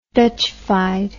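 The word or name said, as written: Dutchified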